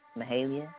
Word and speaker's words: Mahalia: I'm going to play Grateful by Mahalia. I said that right. Mahalia, right? – Yeah.